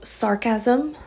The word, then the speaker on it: sargassum: It's called sargassum. Sounds like sarcasm, but I believe that's how you pronounce it. It's S-A-R-G-U-G-A-S-S-U-M, sorry.